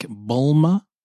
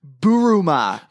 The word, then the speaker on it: Bulma: And then like Bulma, maybe. – No, it's pronounced Buruma.